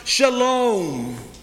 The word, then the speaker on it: shalom: It comes from the Hebrew and is pronounced shalom.